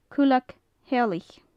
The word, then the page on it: cuilleag theallaich: gaelic